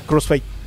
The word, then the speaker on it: Crossfade: Crossfade. – Crossfade, vamos a escuchar esta banda que también descubrí en mis días de moribundez en mi cama.